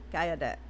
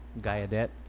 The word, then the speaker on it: Gallaudet: Gallaudet. – Gallaudet.